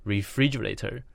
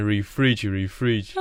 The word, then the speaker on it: refrigerator: 我记得也是冰箱，然后它的英文是 refrigerator。 – 哦，对，我想起来了。哦，真的是，然后我一直念成 refrigerate。